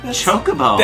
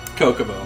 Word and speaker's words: Chocobo: How do you say the yellow birds from Final Fantasy? – Chocobo. – Thank you. – That one's easy. – That's because that's what they're the other pronunciation? – Say it. – Kokomo. – It's not Kokomo, you monster!